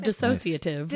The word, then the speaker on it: dissociative: Tell me about disassociative. Did I say it right? – Or sort of dissociative. I think you might have added a syllable, but I liked it.